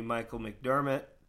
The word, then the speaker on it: Micheal McDermott: On that note, ladies and gentlemen, this evening I have with me Micheal McDermott.